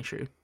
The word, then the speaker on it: Hsu: Stephanie Hsu is how you pronounce the last name. Stephanie Hsu.